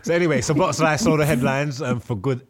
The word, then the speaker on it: Szoboszlai: I can't, Szoboszlai. – Yeah, he said it right, yeah.